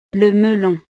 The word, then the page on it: le melon: frances